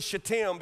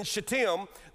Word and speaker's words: Shittim: The Bible says, while Israel lived in Shittim, be careful on your syllable emphasis, while Israel lived in Shittim, the people began to whore with the daughters of Moab.